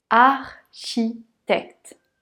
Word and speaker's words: architecte: Architecte on entend le R. Chi, on retrouve le son ch. Tect, le dernier E n'est pas prononcé.